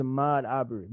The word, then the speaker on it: Ahmaud Arbery: Like, that's what's fucking I'm afraid I may pronounce it wrong, but it's Ahmaud Arbery. Is that me cutting out or you? – Oh, that was you sounding like. But yeah, it's Ahmaud Arbery.